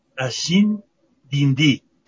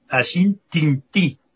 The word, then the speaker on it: Ashin Tinti: Ashin Dindi, pero se escribe Ashin Tinti. El tin suena al din, ¿no? – Ya.